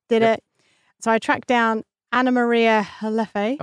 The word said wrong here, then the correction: Heffala: Just jumping in quickly to say it's pronounced Heffala. Heffala. Apologies, Anna Maria. Every time anyone hears me mispronounce it, remember it's actually Heffala.